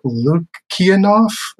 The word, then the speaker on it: Lukianoff: As far as other books, I just started a book called The Coddling of the American Mind by Greg Lukianoff.